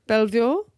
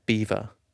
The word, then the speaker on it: Belvoir: Belvoir? Belvoir? – So just B-E-L. – V-O-I-R. Belvoir. – Do you know how you say that one? – How? – Beaver.